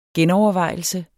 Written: genovervejelse

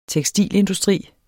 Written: tekstilindustri

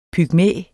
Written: pygmæ

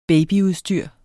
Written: BABYUDSTYR

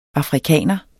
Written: AFRIKANERNE

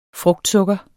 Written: frugtsukker

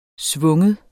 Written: svungen

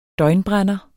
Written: døgnbrænder

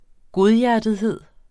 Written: godhjertethed

